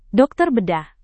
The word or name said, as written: dokter bedah